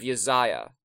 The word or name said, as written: Isaiah